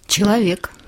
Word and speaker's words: Человек: Человек.